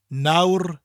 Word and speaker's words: nawr: It's cold at the moment, or now, nawr in South Wales, rwan in North Wales.